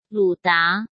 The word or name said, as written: Lu Da